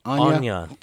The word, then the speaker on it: Anya: Anya.